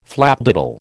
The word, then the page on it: flapdoodle: American English